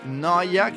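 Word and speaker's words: No14: Noia, che si scrive però N-O-14.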